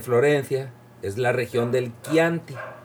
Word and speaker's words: Chianti: Chianti. Y se escribe C-H-I-A-N-T-I.